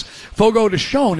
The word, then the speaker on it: Fogo de Chão: Fogo de Chão is, I believe, the correct pronunciation.